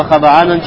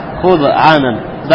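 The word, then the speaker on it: хаба'анан (خضعانا): Сказал хафиз ибн Хаджар, слово хаба'анан читается именно с двумя фатхами. На, минан худо. И это слово происходит от слова смирение, худо. Вафириваятин биламми ауалихи васукун сани. А в одном из риваятов это слово пишется не хаба'анан, а как худ'анан, даммой и сукуном.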